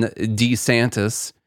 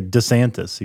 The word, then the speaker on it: DeSantis: The Spaces with Ron DeSantis. Did you know he says it DeSantis. – He doesn't say DeSantis. He says DeSantis.